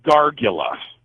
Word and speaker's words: gargola: It's called the Gargula. – The Gargula, okay. – Yeah, it's spelled G-A-R-G-O-L-A.